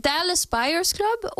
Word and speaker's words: Dallas Buyers Club: Han kom jo ut med Dallas Buyers Club Nei, nå uttalte jeg det feil. Jo, Dallas Buyers Club, og han er med i The Wolf of Wall Street med Leonardo DiCaprio, ja, som kom ut senere.